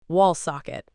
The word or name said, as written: wall socket